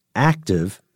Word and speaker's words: ACTIV: DARPA is aiming to build a fleet of unmanned ships called Anti-Submarine Warfare Continuous Trail Unmanned Vessels, to be pronounced ACTIV for short.